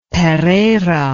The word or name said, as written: pereira